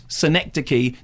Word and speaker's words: Synecdoche: The film is called Synecdoche, pronounced Synecdoche to rhyme with Schenectady. That's the whole point.